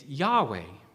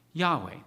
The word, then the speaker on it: Yahweh: But Jehovah is not the name of God. It's actually a deliberate obscuring of the proper pronunciation of the name. Our best guess at the proper pronunciation of God's name is Yahweh. Yahweh, that's our best guess.